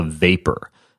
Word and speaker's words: Vapur: This one's called the Vapor. The V-A-P-U-R is how you spell it.